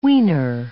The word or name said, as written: wiener